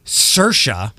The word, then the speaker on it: Saoirse: Say it, S-A-O-I-R-S-E. That's Saoirse. Saoirse Kennedy Hill. – That's a stretch. – Right? – That's reaching. – It's like Siobhan with a B.